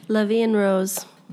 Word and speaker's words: La Vie en Rose: La Vie en Rose.